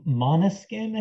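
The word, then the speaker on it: Måneskin: Him and Mainskin. – Yeah. Oh God. – Eurovision winners, Mainskin. Yes. – Monoskin, I think is how it's pronounced. – Maybe I'm calling it Mainskin.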